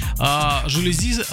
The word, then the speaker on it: жалюзи: «Жалюзи» — слово французское, ударение строго на последний слог.